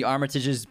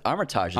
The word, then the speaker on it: Armitage: Then we have the Armitages, played by, how do you say it last? Armitages.